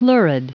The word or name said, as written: lurid